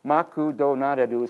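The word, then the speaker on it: Makudonaru (マクドナルド): So, for example, right here, okay, McDonald's, okay, Makudonaru is how you say it in Japanese. It's written right there in the phonetic alphabet.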